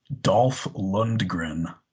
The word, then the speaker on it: Dolph Lundgren: We're going to go with, hopefully I say this correctly, Dolph Lundgren. – You did, yeah. Rocky IV, baby. – You said it exactly.